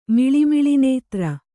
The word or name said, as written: ಮಿಳಿಮಿಳಿನೇತ್ರ